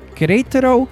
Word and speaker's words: Querétaro: I'm loving this town, Querétaro, Mexico.